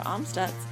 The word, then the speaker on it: Amstutz: You can find me on all social media platforms at Sandra Amstutz.